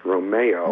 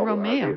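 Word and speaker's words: Romèo: Yes, it's actually pronounced Romeo. – Romeo, the original Italian of, you know, Romeo and Juliet. So he put the little accent on the E to pronounce him in the original Italian name.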